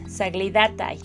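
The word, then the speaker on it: Saglidatai (соглядатай): Así se llama, Saglidat, Saglidatai. Así es el, vocablo ruso, eh, así con el acento en la penúltima sílaba,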